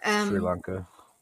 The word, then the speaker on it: Sri Lanka: but probably Asia, like Thailand or Sri Lanka. I don't know how you pronounce that in English. – That's correct, Sri Lanka.